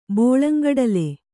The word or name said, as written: ಬೋಳಂಗಡಲೆ